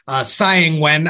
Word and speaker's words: Tsai Ing-wen: But early this morning, Taiwan time, which was really late last night, our time, he met with the Taiwanese president, Tsai Ing-wen. I've been practicing that all day to make sure that I pronounce it correctly. In fact, I even went to some Taiwanese broadcasts just to make sure I was pronouncing it correctly.